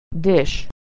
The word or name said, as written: dish